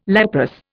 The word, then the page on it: leprous: Indian